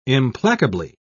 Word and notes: implacably: British English

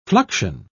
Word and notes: fluxion: British English

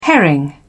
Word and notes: herring: British English

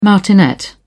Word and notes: martinet: British English

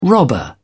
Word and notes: robber: British English

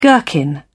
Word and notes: gherkin: British English

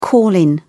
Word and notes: call in: British English

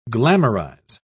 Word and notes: glamorise: British English

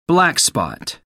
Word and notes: black spot: American English

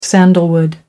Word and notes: sandalwood: American English

